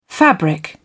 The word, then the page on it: fabric: British English